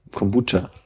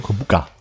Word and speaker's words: Kombucha: Ähm, also Kombu ist eigentlich ja so eine japanische Alge und Cha das japanische Wort für Tee. – Also haben wir beide falsch ausgesprochen. – Also musst ja. du Kombucha sagen? Kombucha.